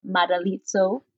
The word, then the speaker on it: Madalitso: And we're so happy to have had Daniel Madalizzo.